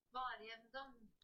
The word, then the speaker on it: Vårjevndøgn: This is how you say it. Vårjevndøgn.